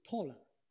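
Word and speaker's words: 托 勒 (תורה): 律法在希伯来文的发音是托勒，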